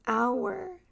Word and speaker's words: hour: And this is one of the exceptions where we don't pronounce the H, hour. We don't say hour.